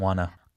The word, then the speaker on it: wanna: I didn't wanna. – I didn't wanna. 그러니까 want to를 이어 발음하게 되면 want to인데 조금 더 빠르게 발음하면 wanna.